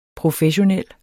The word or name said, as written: professionel